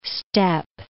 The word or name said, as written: step